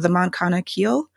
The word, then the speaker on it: Moncana Keel: And the short story that I have in there is called The Moncana Keel, which I know no one ever will be able to pronounce, but that's how you say it.